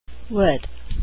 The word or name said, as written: would